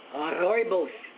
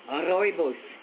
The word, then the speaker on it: rooibos: It's a red bush tea, and they pronounce it, let me get my R right, rooibos tea. They call it rooibos.